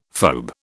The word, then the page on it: phoebe: British English